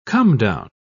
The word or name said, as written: comedown